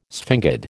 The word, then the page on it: sphingid: American English